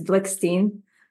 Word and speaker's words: Blikshteyn: It's Blickstein.